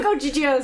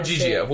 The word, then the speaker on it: Ogygia: Ogyglia? I don't know. – No, I feel like Ogygia is right. Like, I remember my English teacher saying that. Ogygia. We'll go with Ogygia. It's spelled fucking weird. It's O-G-Y apostrophe G-I-A.